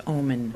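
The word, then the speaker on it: Oomen: Actually, it's pronounced like the word. It's pronounced Ooman.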